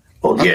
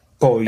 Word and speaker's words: Bolieu: But just look, if you look at it, it's Bolieu. Imagine an R at the end. Bam. It's Bolieu. Bolieu. Okay. Bolieu.